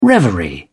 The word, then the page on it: reverie: British English